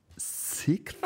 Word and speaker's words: Sixth: Um, ähm, ich rede mal über eine Band, die ich schon mal vorgestellt habe, namens Sixth. – Wie nochmal? Sixth. Wie schreibt man das? Sixth. Oder, oder? Äh, S-I-K. TH, das englische, englische YZ. TH. Also nicht Sixt, der Autoverleih.